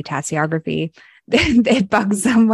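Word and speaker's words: tasseography: Let's first touch on tea leaf reading, tassiography, right? – Yeah, you can pronounce it tassiography or tassiography or even tassiomancy.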